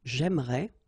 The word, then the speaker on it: j'aimerais: But then it's exactly the same thing, because even if you've got this E here and here, you don't pronounce it. So it will go like j'aimerais, or then j'aimerais. Remember, phonetically, they are the same, okay?